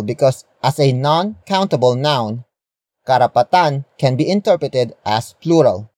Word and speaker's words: karapatan: And that noun is karapatan. Karapatan. Karapatan. Karapatan is spelled as K-A-R-A-P-A-T-A-N.